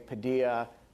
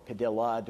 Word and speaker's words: Padilla: citizens who've become terrorist suspects, they often think of people like Jose Padilla, or Padilla, depending on your pronunciation choice, or Najibullah Zazi, or many of the people who've been arrested domestically or detained domestically.